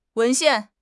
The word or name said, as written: 文献